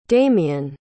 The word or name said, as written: demian